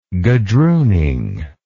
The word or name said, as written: gadrooning